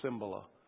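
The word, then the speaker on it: Cymbala: Dale and I went away, and the one thing we both agreed on that we could learn from the conference is how to pronounce his name. It's Cimbala.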